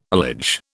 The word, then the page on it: allege: British English